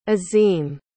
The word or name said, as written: azim